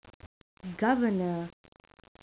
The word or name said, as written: governor